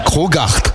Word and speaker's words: Crogacht: Ça s'écrit C-R-O-G-A-C-H-T. – C'est l'art du crocodile. – Le C-H-T, c'est... C'est comme ça. Krogart.